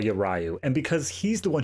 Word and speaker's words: Ryu: It's pronounced Ryu.